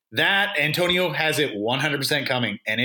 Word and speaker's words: Antonio: Is it Antonio? Did I say that right? – It is. It is the Great Antonio.